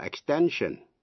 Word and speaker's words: extension: Thế nhưng hai động từ, to extend, và danh từ là extension, comprehend là hiểu, danh từ comprehension, thì cái tiếp vị ngữ shen đọc là shen, extend, extension.